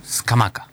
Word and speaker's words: Scamacca: Scamacca. – Qué bien que lo dice, fiscal. – Es como se pronuncia, no es que yo lo digo bien o mal.